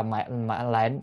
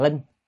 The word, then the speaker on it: lệnh: Lệnh là mệnh lệnh, chữ đó thì đọc là mệnh lệnh chứ không phải đọc là linh,